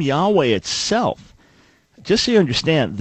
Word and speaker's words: Yahweh: That, according to everything we understand, linguistically and textually and many other reasons, is the best way to pronounce God's name as it was given to the people of Israel, Yahweh.